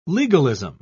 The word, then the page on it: legalism: British English